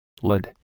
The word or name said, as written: lid